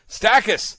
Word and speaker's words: Stachys: And if I'm saying it correctly, is it Stachys floridans? – Stachys. Oh, man. Stachys.